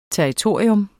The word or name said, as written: TERRITORIERNES